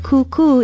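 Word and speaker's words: kūkū: We hear the word tutu used so often, but many of us may not know that kuku is another, perhaps more traditional pronunciation for today's Hawaiian word. It is spelled K-U-K-U with macrons over both vowels.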